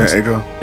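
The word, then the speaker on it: Jhené Aiko: Janae Aiko. – It's Aiko? – Yeah. – Okay, thank you. Because I've heard that, I've heard her name be, like, said 8,000 different ways. – Yeah, it's Janae Aiko.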